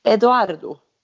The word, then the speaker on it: Edoardo: Hon ska ju då i maj stå brud och gifta sig med sin italienska pojkvän eh, Edoardo. Kan man, man, kanske säga så? Ja, det tror jag. – Bra italienskt uttal.